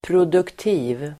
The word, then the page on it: produktiv: [pr'od:ukti:v (el. -'i:v)]